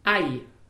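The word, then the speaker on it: aj: AI. – AI, ça s'écrit A-J.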